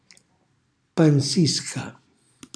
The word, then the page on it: panzisca: calabrese